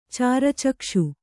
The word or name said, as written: ಚಾರಚಕ್ಷು